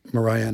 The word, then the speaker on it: Mariah: And they said Mariah, and I said rhymes with pariah, which is a terrible.